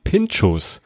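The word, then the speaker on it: Pintxos: Wo ihr diesen Käse noch finden könnt, ist oft auf Pinchos. So, das klingt schon wieder so ein bisschen baskisch, da ist wieder ein X drin in der Aussprache auch.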